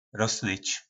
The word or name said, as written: rosnyc